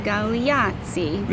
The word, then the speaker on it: Galeazzi: The Galeazzi.